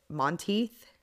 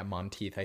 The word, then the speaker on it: Monteith: And of course, a lot of people know that one of the guys from Glee, Corey, is it Monteith? – Yeah, Monteith, I think is how you say it.